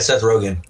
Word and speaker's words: Rogen: Rogen, I think I mispronounced his name. – Yeah, Seth Rogen.